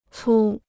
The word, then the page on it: 粗: cū